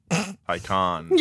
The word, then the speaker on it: Taycan: And he's like, "My Porsche, my Taycan." And I was like, Tycon.